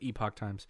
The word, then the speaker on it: Epoch Times: You pronounce it Epic Times or the Epoch Times. I pronounce it Epoch Times.